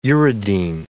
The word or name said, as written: uridine